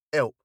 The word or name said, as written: help